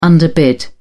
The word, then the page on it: underbid: British English